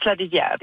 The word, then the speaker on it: Larivière: Well, in French, it's Larivière. Yes.